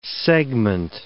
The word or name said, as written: segment